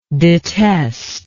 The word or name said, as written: detester